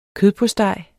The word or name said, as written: kødpostej